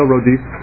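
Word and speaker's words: Raudys: Yes, Leo Rodis, last name is spelled R-A-U-D as in David, Y, S as in Sam.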